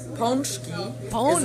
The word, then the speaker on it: pączki: So the proper pronunciation of the word pączki is in Polish. Pączki is many, one pączek. And the, you do not say pączkis or pączki, but it's pączki.